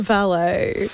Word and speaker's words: Vale: Is it pronounced Vale or Valet? – Vale.